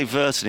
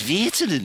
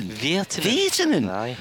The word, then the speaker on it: Virtanen: You have to say his name as though you're a Scouser. Did you know that? – No, didn't. – Because you can't say Virtanen, it's Virtanen. – Virtanen.